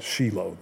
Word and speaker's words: Shiloh: They call it Shiloh. That's how it's actually pronounced.